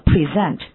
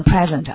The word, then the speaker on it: present: 这个音啊应该读成 present，千万不能读成 present 啊，要 present 当名词用了，当礼物。如果要是 present 呢，是动词，表示赠予，给予。